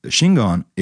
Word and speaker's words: Shingon: Because Shingon is a Japanese way to pronounce the Chinese characters Chen Yin.